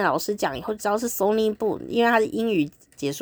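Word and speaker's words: Sonic Boom: 第一个看点就是阿里咕，其实不是阿里咕，它是 Sony Boom，但是这个 Sony Boom 呢，自己人要发音就有点困难吼，所以那个机器有很多音都发不出来的时候，它会变成阿里咕阿里咕阿里咕阿里咕，非常的有趣，所以我就在想阿里咕到底是什么东西啊？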